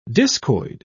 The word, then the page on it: discoid: British English